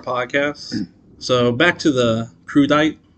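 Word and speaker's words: crudité: I looked up the pronunciation. I had someone pronounce it. An actual Frenchman, it is crudite.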